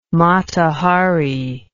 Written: Mata Hari

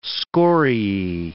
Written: scoriae

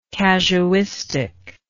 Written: casuistically

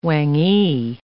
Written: whangee

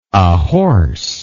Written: ahorse

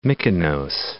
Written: Mykonos

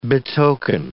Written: betoken

